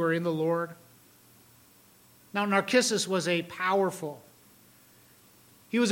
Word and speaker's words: Narcissus: There Paul invites his audience to greet those of the household of Narcissus, or Narcissus. Narcissus would be kind of like the Greek pronunciation. Narcissus would be the Latin one.